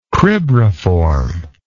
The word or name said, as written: cribriform